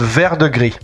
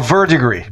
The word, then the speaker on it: Verdigris: No, it's called Verdigris or Verdigris. Both pronunciations are correct.